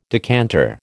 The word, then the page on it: decanter: American English